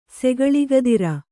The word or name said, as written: ಸೆಗಳಿಗದಿರ